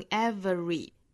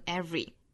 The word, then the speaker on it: every: 所以这个词我们还可以读成 every。那至于到这个 schwa sound 是可以省掉也可以不省的，一般来说我们听到比较多的情况就是读成 every，也就是把这个 schwa sound 省掉的情况哈。